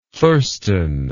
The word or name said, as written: thurston